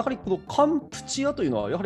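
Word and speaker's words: カ ン プ チ ア (កម្ពុជា): そうそうそう、あのー、だから現地の言葉でカンボジアっていう時はどっちかというと発音的にはカンプチアになるみたいですね。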